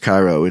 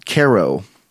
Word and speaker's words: Cairo: because it's spelled like Cairo, Egypt, and it's not pronounced Cairo, it's Cairo.